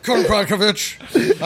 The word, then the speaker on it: Kowalkiewicz: Kowalkiewicz. I checked it phonetically, cross-referenced against the English-Polish dictionary, and then looked up the YouTube pronunciation videos.